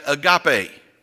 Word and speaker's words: agape: But then there's a third word in the Greek language, and we pronounce that agape.